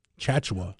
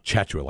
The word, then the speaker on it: Tchatchoua: the last name has a short A sound. It's Jonathan Tchamwa Tchatchoua. Chachua. Chachua, like you're in an online chat.